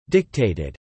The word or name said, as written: dictated